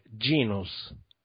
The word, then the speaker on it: Genus: Non genius, non c'è la I, vedi, ogni volta che aggiungi una I il disegno diventa sempre più piccolo. – No, questa cosa poi non me la perdonano, dai. – Ogni volta che aggiungi una I diventa sempre più piccolo. – Sentiamolo dalle tue suadenti labbra. – Che cosa? No, è la pronuncia esatta? – Sentiamo. – Allora, in italiano è genus, senza nessuna I.